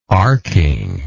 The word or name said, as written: arcking